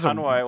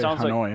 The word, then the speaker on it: Hanoi: That's a weird way to say Hanoi. – Like, I don't know how to say it, sorry. – That's Hanoi. – How do you say it? – Hanoi. – Hanoi? – Yeah, Hanoi.